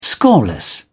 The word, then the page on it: scoreless: British English